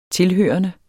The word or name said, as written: tilhørende